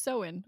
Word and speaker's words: Samhain: Like if you read it, it's Samhain. – And I was like, and then it was in parentheses, was like pronounced Samhain. I was like, oh, all right. – There's a lot of silent letters.